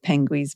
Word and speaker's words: Pengwee's: You can find Penguin's Breath on the internet, and I'll spell it for you. It's PENGWEE'S Breath.